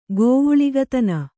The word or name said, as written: ಗೋವುಳಿಗತನ